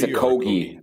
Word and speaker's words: Okogie: Other than Keita Bates-Jop and Josh Okogie. Is that how you say, how do you say his last name? Is it Okogie? – I think it's Okogie. I think it's a hard G, Okogie.